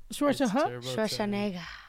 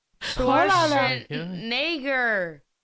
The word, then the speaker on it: Schwarzenegger: Schwarzenegger. – Schwarzenegger.